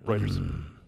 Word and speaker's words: Reuters: No, you said it right, Reuters.